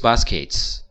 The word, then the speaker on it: baskets: 因为英语当中 t 和 s 在一起，那它就会组成一个新的发音，就是 c c c c c，就像你悄悄的说一次两次的那个次一样的 c c c。 with baskets。